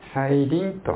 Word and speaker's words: 再臨: 再びという字に望むと書いて再臨と読みます。